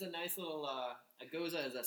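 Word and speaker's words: Gose: Goza is a German word. – Why isn't it pronounced goose? – Because it's a German word.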